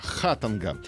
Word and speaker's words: Хатанга: Ударение в названии поселка Хатанга на первую А. Хатанга.